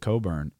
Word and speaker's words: Cockburn: Because Cockburn, whatever you want to call him, I think they pronounce it Coburn, is a man amongst boys.